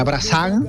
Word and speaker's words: Brassens: Se escribe, ajá, Brassens, pero se pronunciaría Brassens. Uh-huh. Y hay que leer siempre fino, tú sabes que aquí tratamos de hilar siempre fino, sí, porque claro, ¿a quién admiraba profundamente Javier Krahe? Pues a Brassens, ¿verdad?